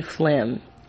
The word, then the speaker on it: phlegm: Man was like those silent letters they just learned about in English class, like the S in island or the G in phlegm.